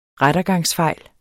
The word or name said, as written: rettergangsfejl